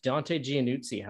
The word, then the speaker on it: Dante Giannuzzi: But yeah, we have got one half of the incredible goaltending tandem from the Portland Winterhawks, from Winnipeg, Manitoba, the man himself, Dante Giannuzzi.